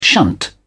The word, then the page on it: shunt: British English